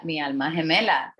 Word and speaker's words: mi alma gemela: Is that how you say it? – Mi alma gemela. That's it. – Did I say it right? – Yeah. Mi alma gemela, sí, está bien, está correcto. Estás correcta.